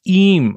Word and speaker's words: Eyam: How do you say it? EYAM. – So, like, if English made sense, right? You'll be EM. But yeah. But no. It's EM, apparently. EAM, so I even wrote it out in my notes how to say it, and then I didn't know how to read that. – There is no I in EM.